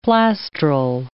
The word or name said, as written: plastral